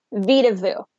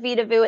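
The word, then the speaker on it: Vedauwoo: You're saying it's spelled like it sounds, so it's V-E-D-A-U-W-O-O. – Yep. – Vitavu is what it looks like. – They pronounce the V like a W, but I don't quite know how they do it.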